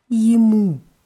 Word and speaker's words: ему: On, jemu. Jemu. Ihm. Mhm. Auch mit Je vorne geschrieben, aber eher wie I ausgesprochen. Jemu.